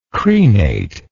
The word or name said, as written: crenate